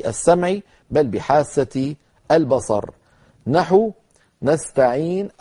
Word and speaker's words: نستعين: وإياك نستعين، نستعين، مجرد ما سكنت النون مباشرة أضم الشفتين ضما ضما كما رأيتم كهيئتهما عند النطق بالضمة.